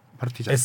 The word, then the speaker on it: 파르티잔: 어, 파르티산 스펠링 맞겠죠? 파르티산. – 파티에서 파르티산. P A R T I T I Z A N. 파르티잔.